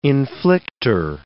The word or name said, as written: inflictor